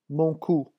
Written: mon cou

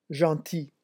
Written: gentil

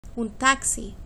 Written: un taxi

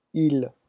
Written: ils

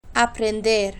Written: aprender

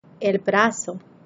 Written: el brazo

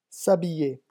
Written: s habiller

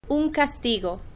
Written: un castigo

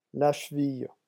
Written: la cheville

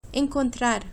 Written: encontrar

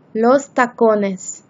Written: los tacones

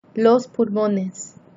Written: los pulmones

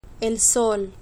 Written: el sol

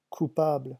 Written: coupable

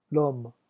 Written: l'homme

